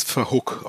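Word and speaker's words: Verhoek: Und das zweite Tor, ein absolutes Traumtor, wird garantiert beim Tor des Monats dabei sein, nämlich verhückt mit einem ja. nach Pröger-Flanke mit einem fantastischen Fallrückzieher, wirklich, äh, ja. ja, fantastisch. – Der heißt, er, heißt, er wird übrigens Verhook ausgesprochen, Ansgar.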